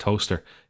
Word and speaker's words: Towcester: That's in Toaster. T-O-W-C-E-S-T-E-R. Pronounced Toaster in the UK.